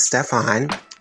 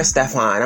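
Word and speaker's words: Stephon'e: I used to always get called Stephanie, never Stefan.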